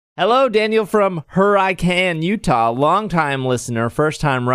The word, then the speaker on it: Hurricane: The pronunciation the pr- pronunciation of my hometown, Hurricane is pronounced her I can, not and not hurricane like it's spelled.